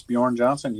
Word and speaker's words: Bjorn Johnson: Yep, you got it right. It's Bjorn Johnson. You even pronounced it right, which is a challenge.